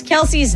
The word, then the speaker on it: Kelce: We are saying Travis Kelce's name wrong. It's actually pronounced Kelce.